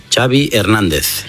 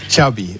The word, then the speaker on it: Xavi Hernández: What is the correct pronunciation of your first and last name? – Xavi Hernández. – Xavi. Okay.